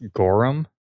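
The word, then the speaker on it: gorram: I want to say it's pronounced Gorum.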